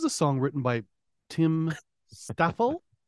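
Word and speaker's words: Staffell: So we have a song here written by Brian May and Tim Staffel before Queen. – That would be Staffel. I'm pretty sure it's Staffel. I think you're pronouncing that with a very Canadian tongue there.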